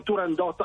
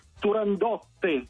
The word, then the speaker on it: Turandot: Allora, il titolo è Turandot all'italiana, così okay. La pronunciava Puccini con i suoi collaboratori, anzi Turandotte in certi casi, addirittura con la doppia T e la E finale in qualche lettera, in maniera un po' così amichevole la chiamavano Turandotte, ma l'opera è Turandot all'italiana.